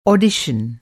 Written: ऑडिशन

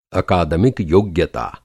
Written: अकादमिक

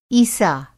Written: ईसा

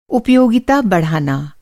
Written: उपयोगिता

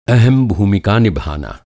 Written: भूमिका

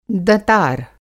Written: दँतार

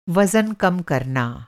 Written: वज़न

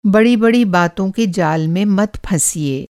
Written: बातों का जाल

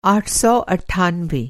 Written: आठ सौ अट्ठानवे

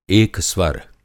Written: एकस्वर

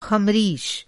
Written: ख़मरीश

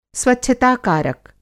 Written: स्वच्छता कारक

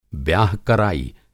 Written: ब्याह कराई

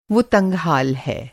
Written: तंगहाल

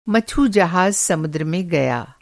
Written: मछुजहाज़

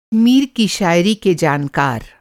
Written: जानकार

